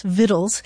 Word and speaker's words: victuals: So today you'll see either the word victual spelled V-I-T-T-L-E or V-I-T-T-L-E-S, victuals, And you'll also see it spelled V-I-C-T-U-A-L-S, vittles. Mm-hmm. And you look it up in the dictionary, and the pronunciation is vittles for both words.